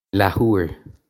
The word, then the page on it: leathuair: lah-hoo-ir; Irish